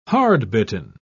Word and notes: hardbitten: British English

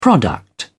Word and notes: product: British English

